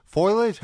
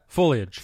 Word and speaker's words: foliage: Foliage? How do you say that? – Foliage. – Foliage. – Foliage. – There we go. Nice.